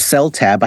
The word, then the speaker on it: Seltaeb: Epstein was once he saw that the merchandise, which was Seltaeb, I think it's how you pronounce that. – Yeah, Paul's Seltaeb. – Yeah, Beatles, sp- Beatles spelled backwards.